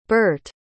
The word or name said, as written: burt